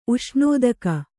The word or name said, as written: ಉಷ್ಣೋದಕ